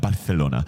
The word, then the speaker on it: Barcelona: I remember, like, the most ambitious run I ever took was we were on vacation in Barcelona, which you have to say it like that, Barcelona.